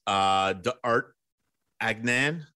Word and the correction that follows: D'Artagnan: D'Artagnan? – Yeah, sure. Let's go with that.